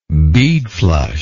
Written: beadflush

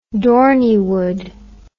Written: dorneywood

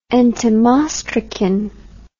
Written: entomostracous

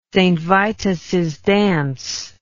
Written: saintvitussdance

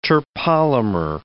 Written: terpolymer

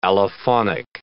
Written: allophonic

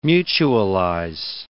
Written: mutualize

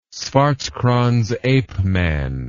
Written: swartkrans ape man

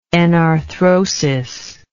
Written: enarthrosis

